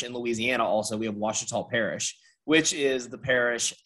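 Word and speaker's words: Ouachita: It is Wachita. – Okay. – Pronounce it like it's a W.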